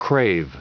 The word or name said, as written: crave